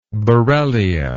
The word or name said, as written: borrelia